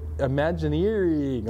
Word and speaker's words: Imagineering: Okay, Imagineering is what goes on when Walt Disney, where I used to work, but this is Imagineering, like eerie.